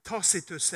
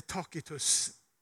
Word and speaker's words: Tacitus: Och den romerske historikern Tacitus, eller Tacitus, det finns, man kan uttala det på båda sätten, har jag kollat, skriver att Felix hängav sig åt grymhet och lust som en kung, men hade en slavs sinne.